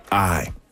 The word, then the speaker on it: Ahi: A-H-I, simply pronounced I.